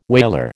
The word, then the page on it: whaler: American English